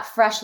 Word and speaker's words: Fraiche: So it's at Fresh Living, spelled the same way, F-R-A-I-C-H-E Living.